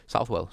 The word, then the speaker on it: Southall: Yeah, well, I say Southall. I've been brought up saying Southall. – Yeah. – But I was told that the correct pronunciation and people of Southall say Southwell, so...